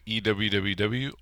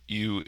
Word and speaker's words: EWWW: There's two different ways. You can either do it EWWW or u Image Optimizer.